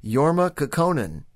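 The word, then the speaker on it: Jorma Kaukonen: I can even pronounce the guitar player's name, Jorma Kekkonen.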